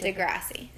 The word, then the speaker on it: Degrassi: Did I say it as a Degrassi? Is it Degrassi? – Is that how you say it? – Yes.